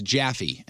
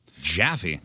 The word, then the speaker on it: Jaffe: My first name is Jaffe, and it's spelled J-A-F-F-E. – Jaffe? – Jaffe.